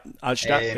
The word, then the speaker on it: Altstadt: So it was in I might not pronounce this right, the Altstadt of Dusseldorf. – Yep, Altstadt, yeah.